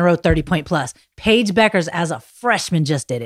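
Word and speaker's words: Bueckers: A lot of people think her name is Bukers because of the way it's spelled. It's Paige Beckers.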